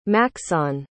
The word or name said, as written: maxon